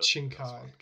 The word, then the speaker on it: Shinkai: But Shinkai. – Okay. Shinkai is probably fine as well. – Shinkai.